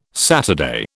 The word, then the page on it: Saturday: British English